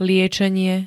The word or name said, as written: liečenie